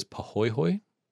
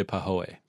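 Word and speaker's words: pāhoehoe: So one way of pronouncing it is pahoehoe. And the other one is pahoehoe. – Pahoehoe. – Oh, sorry, pahoehoe. So pahoehoe or pahoehoe.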